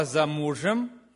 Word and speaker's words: замужем: Она зашла и стала за мужем, и положила руки на плечи мужу и ответила: вот я стою за мужем. Замужем, за мужем, ударение другое. Служитель ответил: так и живите, не забегай вперед, будет хорошо.